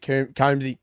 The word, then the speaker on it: Combsy: I've got to admit, Combsy. – I've been calling him, it's Combs like a hair comb, but I was calling it Coombsy.